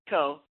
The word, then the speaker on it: Kho: It was K-H-O, so still a little tricky to look at and then say. But once, you know, you get it, it's like, oh, yeah, that's easy.